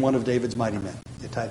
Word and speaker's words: Ittai: One of my favorites because his name rhymes with his home city, Ittai the Gittite.